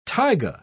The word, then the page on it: taiga: British English